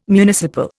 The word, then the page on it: municipal: Indian